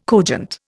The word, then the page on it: cogent: Indian